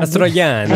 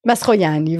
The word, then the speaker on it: Mastroianni: Mastroianni. – Elle appelle Mastroianni, voilà.